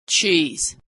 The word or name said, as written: cheese